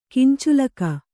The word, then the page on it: ಕಿಂಚುಲಕ: kinculaka